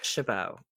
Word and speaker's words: Chabot: Okay, but is it Shabbat, like, phonetically spelled, or is it like the French would say Chabot? – I've heard it both. – Because all I heard last night was Shabbat, and part of me was cringing, thinking, I'm pretty sure that you don't pronounce the T.